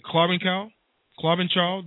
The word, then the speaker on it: Klobuchar: In Minnesota, Amy Klop, somebody help me out with that. Klobuchar, the independent. I never could pronounce the name. She takes it. – You got it.